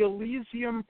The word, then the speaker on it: Elysium: Elysium is E-L-Y-S-I-U-M project, and that means in Greek mythology and philosophy, Elysium is the highest heaven that the human mind can conceive.